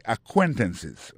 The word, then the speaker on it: acquaintances: c'est acquaintances, hein? Accent tombé sur deuxième syllabe là.